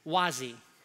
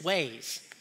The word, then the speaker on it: Waze: The reason I'm saying that is because a long time ago, probably 10, 12 years ago, I started using this app, this GPS app called Wazi. And it wasn't until my wife started using it that she corrected my pronunciation and said it's Waze.